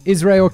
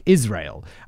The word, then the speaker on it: Israel: Like, we say his name is like the country, like Israel. And I know Americans say Israel. So, I don't know if it's Israel Keys or Israel Keys. I'm definitely probably just going to go with Israel, like the British way, because if I try and say it, let's see if this Israel Keys.